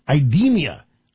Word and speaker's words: IDEMIA: The company in question, I believe, is pronounced IDEMIA, I-D-E-M-I-A.